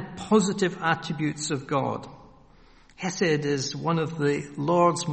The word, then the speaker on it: Hesed: It's H-E-S-E-D, Hesed. Hesed. And it's difficult to translate because we don't have something like it in English.